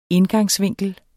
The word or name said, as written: indgangsvinkel